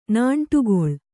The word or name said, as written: ನಾಂಟುಗೊಳ್